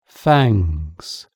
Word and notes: fangs: British English

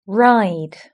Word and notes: ride: British English

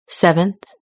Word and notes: seventh: British English

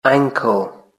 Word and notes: ankle: British English

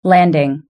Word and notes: landing: British English

